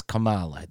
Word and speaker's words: Kamala: But it's Kamala. That's how you say it. You say it right.